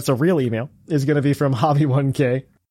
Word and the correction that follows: Hobie: Hobie. – I always say this wrong. Hobie. Hobie1K.